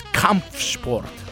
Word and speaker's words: Kampfsport: I asked my friend Patrick yesterday to send me an audio message on how to pronounce this name of this German band. And it's Kampfsport.